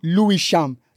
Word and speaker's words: Lewisham: It's L-E-W-I-S-H-A-M. Lewisham.